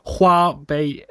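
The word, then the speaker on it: Huobi (花呗): Ja, absolut. Huobi och Jiebei uttalar man dem.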